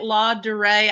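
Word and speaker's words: Ladurée: When I was in Paris years ago, I went, if you say it, La Durée, I don't even know how to say it. – You nailed it.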